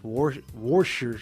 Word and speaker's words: Worcester: I believe they're officially pronounced Worcester Sharks.